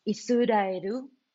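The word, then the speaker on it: Isudaeru (イスラエル): When they take their first class on Judaism or modern Hebrew class, Israel, first of all, the word Israel is not pronounced like that in Japanese. The Japanese word is Isudaeru.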